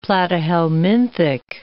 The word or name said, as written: platyhelminthic